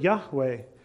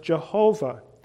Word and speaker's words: Yahweh: And thus, when you read through the Hebrew Old Testament, the name that is used for God is how we would say to pronounce it, Yahweh, sometimes pronounced Jehovah.